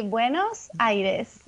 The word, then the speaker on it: Buenos Aires: She was born in, do we say Buenos or Buenos Aires? How would you pronounce it? – So we say Buenos Aires.